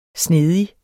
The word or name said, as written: snedig